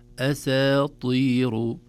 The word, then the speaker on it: أساطير: الطاء بفخامتها، فنقول أساطير. ولو أحببنا أن نعلمها للطلاب مقطعة نقول أسى، أسى، وكلمة أسى يمكن يعني ليست غريبة على الأذن، ثم نقول طير، أسى، طير، أسى، طير، تقطيعها لبيان نطقها الصحيح ليس فيه شيء.